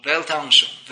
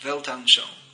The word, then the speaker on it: Weltanschauung: Because if you don't agree with their Weltanschauung, is that how you say it? It's German word? – Weltanschauung. – Weltanschauung.